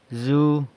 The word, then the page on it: zu: is standing